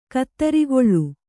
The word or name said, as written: ಕುದುರುಗೊಳ್